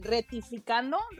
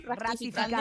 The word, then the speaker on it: ratificando: Retificando, ratificando.